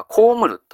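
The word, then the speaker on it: 被る: 本動詞の読み方は、こうむるという読み方です。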